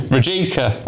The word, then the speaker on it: Rijeka: Rijeka. – I've been to Rijeka. I know it's pronounced Rijeka.